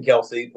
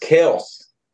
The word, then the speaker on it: Kelce: The Kelseys, because you know it was Travis Kelce and then Jason Kelce played. You know, their actual name is pronounced Kels.